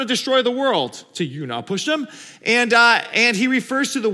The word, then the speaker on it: Utnapishtim: The hero's name is spelled UTNAPISHTIM. I didn't know how to pronounce that. I looked it up. It's called, you pronounce it, you now pushed him. Something like that, close.